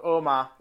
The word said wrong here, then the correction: Otmar: isn't it? Is it something like that? – Yeah, Otmar, that's it.